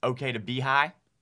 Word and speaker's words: Oktibbeha: We pronounce it okay to be high.